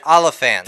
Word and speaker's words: Olyphant: Yeah, Olyphant.